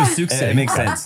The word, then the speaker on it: Sioux City: No, it's there's a lot of vowels in an X. – S-I-O-U-S. – Yes. – Yes. Sioux. It's just, it kind of makes sense.